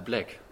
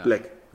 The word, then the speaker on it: Blæk: Wie spricht man die eigentlich aus? Black, Blake? Äh, Black. – Ja. Black, wie genau.